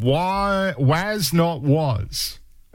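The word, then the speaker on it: Was (Not Was): Waz Not Was. That's how you pronounce them.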